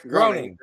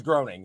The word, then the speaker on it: Groening: Fuck, I don't know how to pronounce his name. Gehring? – Same dude that sees Groening. – Yeah, we'll say that. – Yeah. – We're going to listen and just be like, yep, that's how you say it. – I think I think. – It's Matt Groening, the Simpsons guy. – It is Groening.